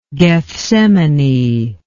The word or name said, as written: gethsemanic